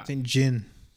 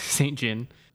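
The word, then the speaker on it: Saint JHN: I assume that's how Saint J-H-N is pronounced. – Yeah. – But yeah. – Saint Jin. – Yeah, Saint Jin.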